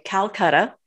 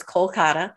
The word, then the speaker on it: Kolkata: Calcutta. Mm-hmm. In English, the Indian way to pronounce it is Kolkata.